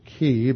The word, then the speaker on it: chi: which was the Greek letter chi, or we can now pronounce it chi, but that's where we get the term chiasm, and the center part is the most important part.